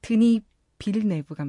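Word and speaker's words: Denis Villeneuve: 드니 벨네브 빌네브 어, 발음이 너무 어렵네요. 드니 빌네브 감독의 컨택트라는 영화.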